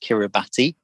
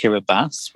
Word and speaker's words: Kiribati: If you were to write it down, it looks like it's pronounced Kiribati, but it's pronounced Kiribati, which I got wrong for a very long time.